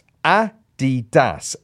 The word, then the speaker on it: adidas: It's pronounced Adidas, as the guy's name was Adidasler.